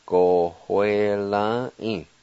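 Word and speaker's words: gowelvi: Second part, let me just remind you that right between the O and W, there's a faint H sound as you pronounce this. Pronounce like this. Go, hue, la, I.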